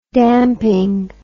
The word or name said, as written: damping